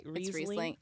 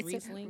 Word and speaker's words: Riesling: It's a Riesling. Am I saying that right? – Riesling. It's Riesling. Riesling. – Excuse me. – You got to say it with the accent.